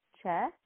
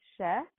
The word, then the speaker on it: Che: it's pronounced Che, not She.